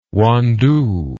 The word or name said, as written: wandoo